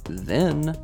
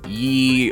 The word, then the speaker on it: ye: Not to be the actually guy, but it's actually pronounced the. The Y is actually just a ligature for contracting two letters.